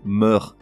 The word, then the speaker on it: mœurs: Ça tue, hein Mœurs ce mot féminin pluriel qui se réfère aux habitudes de vie, aux comportements individuels, s'écrit M-O-E collé e U-R-S. Pour quelle raison prononce-t-on le S final Aucune idée, mais c'est clairement une erreur, ce S est muet. On doit donc prononcer meurt, les meurs.